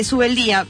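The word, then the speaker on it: Zubeldía: este apellido me cuesta pronunciarlo, Subeldía, pero... – ¿Pronunciaste bien? – Sí, pero... – No como lo del principio. – Pero como que tiene la Z y la D ahí como raro.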